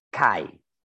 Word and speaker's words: call: Eh, em, es curiosamente, en, catalán no se llama judería, se llama CAI. C-A-L-L. CAI. Es pronunciado así.